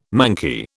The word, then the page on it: manky: British English